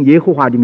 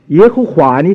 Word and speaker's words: 耶和华: 现在我们不知道本来怎样读法，那么现在的耶和华呢，是根据另外一个某音的读音出来的，是根据那个主子，希伯来人叫 Adonai，耶和华，听见吗？啊，同样的读音。 Yeah，所以呢，这个耶和华这个读音啊是不准确的。